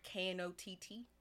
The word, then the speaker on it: Knott: Like Knott. – Oh, okay. – K is silent.